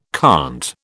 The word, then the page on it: conte: British English